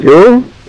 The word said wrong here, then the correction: jowl: Jowl, all right. – Jowl. – Jowl. Is that how you pronounce it? – J-O-W-L. Spell, I think, jowl.